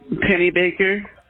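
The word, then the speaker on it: Pennybaker: E-L-O-I-S-E? – Yes, ma'am. – Okay, and what is your last name? – It's Penny Baker. I tell them it rhymes with salt shaker, like the song.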